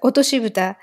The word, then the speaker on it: Otoshibuta: Oshinta Buta, am I saying that correctly? – Just Otoshi Buta. – Oshinta Buta. – Yeah, it's a Otoshibuta. Yeah, otosu means drop, so drop lid, otoshibuta.